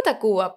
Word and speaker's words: Tacvba: Yo digo Tacuba, pero es T-A-C-V-B-A pero yo digo Tacuba porque es más fácil de, pronunciar.